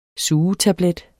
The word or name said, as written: sugetablet